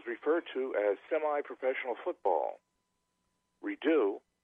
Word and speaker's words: Redux: By the way, the title is a mispronunciation. It's Redo. – Oh, I'm sorry. Okay. My error, R-E-D-U-X, pronounced Redo.